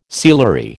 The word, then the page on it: sealery: American English